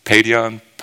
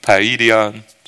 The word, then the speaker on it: paidion: The word for child used here is, I'm going to spell it for you in English P A I D I O N. Padion, paedion, something like this, which indicates a little or young child, or maybe even an infant.